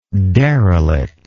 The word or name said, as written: derelictness